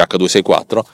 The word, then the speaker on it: H.264: Di solito non si dice il punto, si dice H.264 che è un algoritmo pensato per,